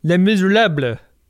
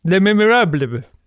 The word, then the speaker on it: Les Misérables: Well, I know your pronunciation is dead wrong, Ben. It's supposed to be called Les Misérables. Try it. Les Misérables.